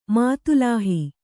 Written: ಮಾತುಲಾಹಿ

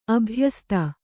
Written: ಅಭಯಸ್ತ

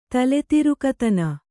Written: ತಲೆತಿರುಕತನ

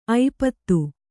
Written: ಐಪತ್ತು

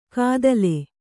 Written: ಕದಲೆ

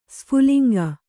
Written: ಸ್ಫುಲಿಂಗ

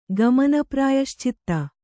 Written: ಗಮನಪ್ರಾಯಶ್ಚಿತ್ತ

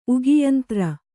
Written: ಉಗಿಯಂತ್ರ

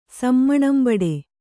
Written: ಸಮ್ಮಣಂಬಡೆ

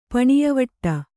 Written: ಪಣಿಯವಟ್ಟ

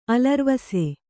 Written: ಅಲರ್ವಸೆ

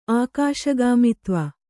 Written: ಆಕಾಶಗಾಮಿತ್ವ

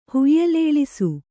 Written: ಹುಯ್ಯಲೇಳಿಸು

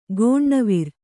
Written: ಕನವರಿ

